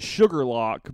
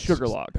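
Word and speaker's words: Shuqualak: Well, it's like in Mississippi, you pronounce it Sugarlock, but it's like Shuquualak. – Yeah, but it's Sugarlock.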